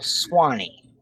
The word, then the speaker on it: Swanee: She would say, well, I'll Swanee. S-W-A-N-E-E. And it's pronounced Swanee. Swanee or Swanee.